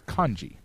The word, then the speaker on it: kanji: So the correct pronunciation of the character system in Japanese is kanji, because... K-A is always rendered as ka, okay? – Yeah. – It's ka-ki-ku-ke-ko, okay? That's how, Japanese syllables work, okay?